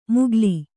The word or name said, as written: ಮುಗ್ಗಾಲಿ